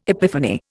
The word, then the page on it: epiphany: Indian